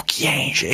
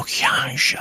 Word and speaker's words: Kyenge: Si pronuncia Kyenge o Kyenge? E gli suggeriscono: meglio Kyenge alla francese. E allora poi il segretario: ecco il ministro Kyenge.